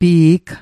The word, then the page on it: pi'g: Mi'gmaq